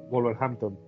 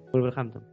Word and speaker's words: Wolverhampton: No, no, no, es, W-O-L-V-E-R Hampton. Ah, Wolverhampton. Wolverhampton.